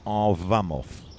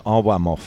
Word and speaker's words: Arłamów: We're in Arłamów, spelt A-R Polish L A M O W, Arłamów. It could be Arwamov. It depends if you say Vodge or Vudge. So we'll call it Arłamów for the sake of it.